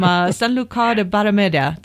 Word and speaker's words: Sanlúcar de Barrameda: And then there's Manzanilla out there and from Sanlúcar de Barrameda. Did I not say that right? – You did very well.